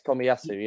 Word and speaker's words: Tomiyasu: Yes, Tomiyasu, yes.